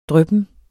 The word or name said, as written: dryppen